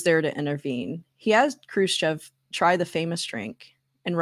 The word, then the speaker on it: Khrushchev: But Nixon, being Nixon, got into an argument with Khrushchev. Is that how you say it? Khrushchev? – Khrushchev? – Khrushchev.